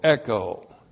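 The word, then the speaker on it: echo (ἔχω): E-C-H long O, echo.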